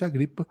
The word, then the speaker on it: Agripa: Marco Agripa, muita gente fala Magripa ou simplesmente Agripa.